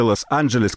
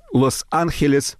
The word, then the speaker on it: Лос-Анджелес: Это Виктория де Лос-Анджелес, как она произносится по-английски, или Лос-Анджелес по-испански, потому что она испанка.